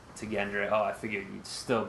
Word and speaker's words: Gendry: you'll definitely see that Gendry, hard G on the Gendry, still rowing meme.